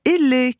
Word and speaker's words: Elhee: Vous avez créé l'entreprise Elle, qui s'écrit E-L-H-E-E.